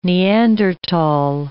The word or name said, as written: Neanderthal